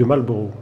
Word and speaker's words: Marlborough: Comme vous l'avez parfaitement prononcé à l'anglaise, son nom, je vais me, ah, permettre, euh, Ian, de le prononcer très mal à la française, ou sinon on ne comprend pas. C'est le duc de Marlborough.